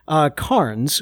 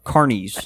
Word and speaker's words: Carnes: He says, "I'm going to help you out with my last name," and he actually spells his name out phonetically. – Carnes. Right, because Bo would want to say Carnes or Carnies.